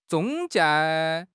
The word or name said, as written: zhong1 jie4